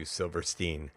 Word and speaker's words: Silverstein: If you'd like to learn more about Matthew Silverstein, uh, you can Google his name. It's spelled exactly the way it sounds with Matthew and Silver and Steen.